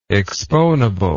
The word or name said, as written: exponible